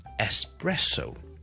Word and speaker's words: espresso: 其中一个最常听到嘅咖啡室英文嘅错误咧，就系、是、咧一个好特浓嘅咖啡，我哋一个 espresso，e s p r e s s o，e s p r e s s o，佢系 espresso，重音系第二个音字啦，而第一个 syllable 记住 e s 咧系唔需要加个 k 音喺度。